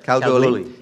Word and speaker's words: Kalgoorlie: He's head of medicine at, is it pronounced Kalgoorlie Hospital. Yeah, that's correct. – Kalgoorlie yeah.